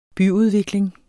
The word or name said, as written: byudvikling